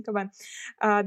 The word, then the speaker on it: Кабан: А дальше Казанская набережная Кабана я могу неправильно ударение ставить, но вроде как кабана, кабана да. все правильно.